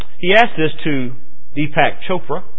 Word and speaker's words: Chopra: And there were others, a man by the name of Deepak, I don't know how to pronounce his last name, Shakur? It's sure not related to the spelling of it then. C-H-O-P-R-A. Chopra? Deepak Chopra. Like Oprah with cha. Chopra.